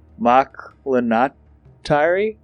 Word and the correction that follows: McIntyre: Dude, it's McIntyre.